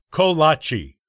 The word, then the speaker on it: Kolache: Let's start with the pronunciation, which is Kolache.